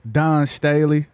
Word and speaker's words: Dawn Staley: Dawn Staley.